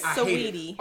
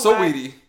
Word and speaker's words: Saweetie: Oh, is her name Sweetie? – It's Saweetie. – It's Saweetie. – I hate it. I hate it. – The A, you gotta pronounce it. It's Saweetie.